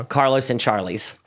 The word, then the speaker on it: Carlos and Charlie's: Yo, we went to this place called Charlos and Carly's. No, Carlos and Charlie's. Said it wrong again.